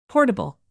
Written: portable

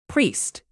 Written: priest

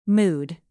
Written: mood

